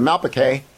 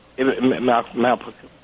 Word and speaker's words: Malplaquet: And it begins at Blenheim and it ends at, I always say it right, is it Malplaquet? – Yeah. – Okay. – Malplaquet.